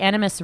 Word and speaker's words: Animas: In case you guys haven't seen it, on the news since about August 5th, there have been some photos of the Animas River, and it's out in Colorado. And how do you spell that? – A-N-I-M-A-S.